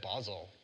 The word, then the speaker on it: Basel: Basel, like nozzle, right? They told me Basel like nozzle. Basil. Yeah, they said Basel when I was there. Yeah, yeah. Uh, there's a study from the University of Basel.